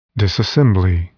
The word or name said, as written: disassembly